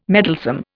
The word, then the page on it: meddlesome: Indian